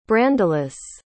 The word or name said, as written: brandelis